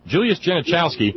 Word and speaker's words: Genachowski: that Julius Genich... I need a pronouncer. Julius Genachowski? Julius Genachowski, yeah.